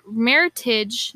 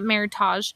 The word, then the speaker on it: Meritage: We pronounced that wrong last episode, but Meritage, not Meritage, is grown.